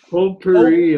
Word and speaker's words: potpourri: Give me the Pulp Panora for 400. – Pulp Panora. – Oh, I forget it. – Potpourri. – Potpourri. – That's for 400. Said it wrong.